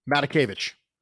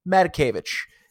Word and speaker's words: Matakevich: Let's say, what, pronounce the linebacker's name for me. – Matakevich. – Matakevich, Madagascar, Matakevich. Okay.